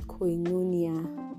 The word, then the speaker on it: koinonia: Fellowship in the Concordance Bible means koinonia. Koinonia is spelled K-O-I-N-O-N-I-A.